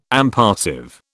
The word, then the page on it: impassive: British English